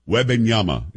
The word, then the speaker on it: Wembanyama: Wembanyama is how you pronounce it.